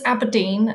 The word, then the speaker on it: ABRDN: It was decided that ABRDN, which is pronounced as Aberdeen and spelt without any E's, was the perfect solution.